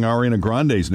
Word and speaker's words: Ariana Grande: Pronouncing Ariana Grande's name wrong. – That's one. – Ariana Grande. Now we know.